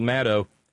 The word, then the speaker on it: Maddow: And there was some discussion between Rachel Maddow, rhymes with shadow,